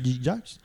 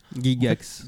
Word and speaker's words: Gygax: Mais Donjons et Dragons, c'est le premier JDR donc, qui a été créé par Gary Gygax. – Gygax. En fait, j'arrive pas à prononcer. Gygax. C'est G-Y-G-A-X.